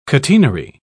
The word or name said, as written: catenary